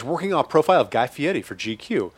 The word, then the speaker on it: Guy Fieri: He told me that's how he pronounces his last name. I know he was born Guy Fieri, but now he pronounces it Guy Fieri.